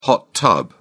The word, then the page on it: hot tub: British English